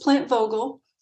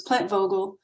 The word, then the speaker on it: Vogtle: It's pronounced Plant Vogtle. In my mind, I pronounce it Plant Vogtle, Vogtle, because that's how you spell it. It's V O G T L E, but the T is silent, so it's Plant Vogtle.